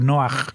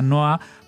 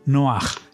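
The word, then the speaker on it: Noaj (נח): no es tal porque Noé en hebreo se dice Noaj. Así que bueno, en este caso sería una Noa, pero la hey final ya se pronunciaría como una het, ¿no? Noaj en vez de Noa.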